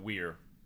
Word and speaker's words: Weir: How do you pronounce his name? Yeah, he pronounces it Weir.